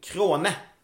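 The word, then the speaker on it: Crona: Krone ska det uttalas.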